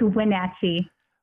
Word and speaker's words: Wenatchee: It's Wenatchee. – Wenatchee, and they are the parents mm-hmm.